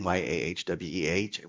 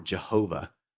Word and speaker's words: Yahweh: We don't know the vowels, so scholars put in YAHWEH, and we pronounce it Yahweh, or Jehovah is another way of pronouncing it.